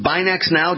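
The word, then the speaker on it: BinaxNOW: Is it Binax Now? Did I say that correctly, the self-test? – You said that correctly. Perfect.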